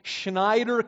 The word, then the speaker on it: Snyder: I was from Snyder County, Pennsylvania, and I spelled it S-C-H-N-Y-D-E, Schneider County. That's the way everybody said it. And I just spelled it the way I heard it pronounced by others.